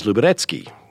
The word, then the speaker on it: Luberecki: Nedski came from, it was a nickname in the Boy Scouts, I think, because the real Polish pronunciation, see, I'm a professional at this, of Luberecki is Luberecki.